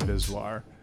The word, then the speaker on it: VSWR: Yeah, so the name Visvar comes from a phonetic pronunciation. A phonetic pronunciation of an acronym, VSWR. It's an electrical type of word. So when you say it, it's just Visvar.